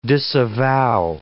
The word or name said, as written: disavow